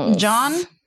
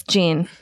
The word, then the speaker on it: Jean: J-E-A-N. – Jean Simmons. – John? – Jean Simmons? – That's John to me. – No, it's Jean. But Jean. It's just like blue jeans.